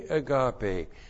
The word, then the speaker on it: agape: That's the definite article, hey, and then agape, A-G-A-P, long E, hey, agape.